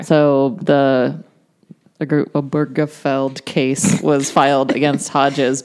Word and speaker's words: Obergefell: I don't think that's how that's pronounced. – Obergefell, I think it is. – Yeah. – Obergefell.